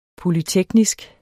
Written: polyteknisk

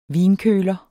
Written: vinkøler

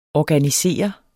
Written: ORGANISERE

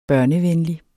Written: børnevenlig

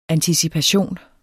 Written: anticipation

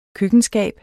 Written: køkkenskab

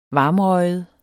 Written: varmrøget

